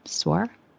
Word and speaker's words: SOAR: How do you say that? Sore. Oh, sore. Um, SOAR, yes. Sore like saddle sore.